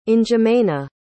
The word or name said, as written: n'djamena